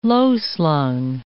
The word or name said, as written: low-slung